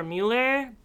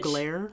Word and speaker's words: Mugler: Dominic Jackson for Mueller. Bitch. – Mugler.